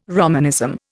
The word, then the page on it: Romanism: Indian